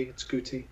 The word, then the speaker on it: Guti: No, it's a hard G. It's Guti.